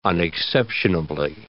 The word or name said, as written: unexceptionably